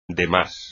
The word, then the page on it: demás: espagnol